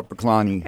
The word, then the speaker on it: Korpiklaani: All right, so we just heard Korpiklaani. Am I pronouncing that correctly? – Yes, Korpiklaani.